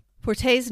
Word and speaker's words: Forte: Forte's name, and I believe I'm pronouncing that correctly, it's F-O-R-T-E. – Forte, yeah.